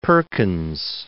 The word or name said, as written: Perkins